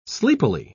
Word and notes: sleepily: British English

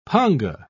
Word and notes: panga: British English

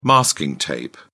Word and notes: masking tape: British English

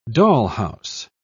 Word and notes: dollhouse: British English

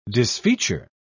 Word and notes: disfeature: British English

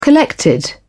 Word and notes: collected: British English

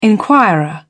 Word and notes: inquirer: British English

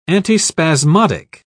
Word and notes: antispasmodic: British English

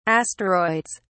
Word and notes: asteroids: British English

